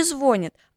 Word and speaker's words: звонит: Мой сосед незнайка стонет, телефон его не звонит, аппарат хитрец молчит, ждет, что кто-то позвонит. Итак, правильно говорить с ударением на «и». Звонит.